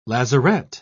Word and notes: lazarette: British English

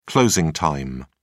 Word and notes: closing time: British English

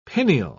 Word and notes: pineal: British English

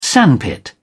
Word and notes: sandpit: British English

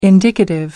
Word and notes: indicative: American English

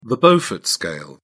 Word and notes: beaufort scale: British English

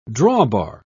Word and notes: drawbar: British English

